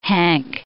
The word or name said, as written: hank